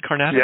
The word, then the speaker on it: Carnatic: Speaking of plugging into another experience, you spent some time studying in India, studying Carnatic, is that how you pronounce it? Carnatic? – Yeah.